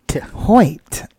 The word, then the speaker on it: Hoyte: Am I pronouncing that correctly? – Hoite.